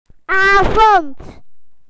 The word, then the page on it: evening: British English